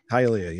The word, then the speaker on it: Hialeah: Hialeah, yeah.